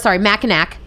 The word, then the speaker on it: Mackinac: So spelled Mackinac, Mackinac, but pronounced Mackinac because French.